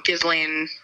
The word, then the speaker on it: Ghislaine: And I couldn't agree more with his coverage on Instagram about the Gaylene, or however you say her name. I can never say it right. – Oh, Ghislaine. – Yes, thank you.